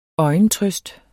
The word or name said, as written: øjentrøst